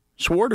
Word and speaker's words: sword: Sword or sword? – Definitely sword. The W is silent.